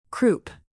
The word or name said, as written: croup